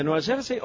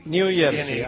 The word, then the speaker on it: New Jersey: ¿Nueva Jersey o Nueva Jersey? ¿Nueva Jersey? – New Jersey. – New Jersey, ahí está, ahí está.